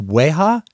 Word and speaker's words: Weijia: Her name is, I hope I get the pronunciation of her first name right. I think it's Weiha, W-E-I-J-I-A, Weiha Yang.